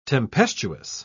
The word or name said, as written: tempestuous